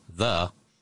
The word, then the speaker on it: Ye: No, Ye, Y-E, is actually pronounced the in English. – Oh, Ye, okay, yeah. Okay. – The. – Yeah, okay. – Like as in, like, ye old general store. It's actually the old general store because that Y in old English was pronounced as a hard T-H.